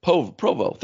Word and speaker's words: Provo: BYU's at home there in Polo, or I think it's Polo or however you pronounce that, Utah. – Provo? – Provo, thank you.